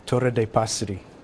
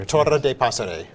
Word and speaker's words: Torre de Passeri: Torre de Passeri. – Okay, so you have to roll your R, first of all. – I guess if you're... – Torre de Passeri.